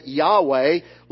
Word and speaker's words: Yahweh: We pronounce it Yahweh, later Val pointed to give us the name Jehovah.